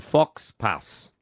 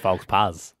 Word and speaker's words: faux pas: Look, I thought we could ask 131060, what's your fashion faux pas? Or fox pas? – Oh, faux pas. I actually thought it was pronounced faux pas.